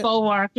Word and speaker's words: Bulwark: Is it the Bulwark? Is that how you say it? – The Bulwark. Yeah, it's the old Weekly Standard.